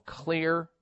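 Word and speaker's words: Xlear: I would recommend you try something called Clear Nasal Spray, and it's spelled with an X, X-L-E-A-R.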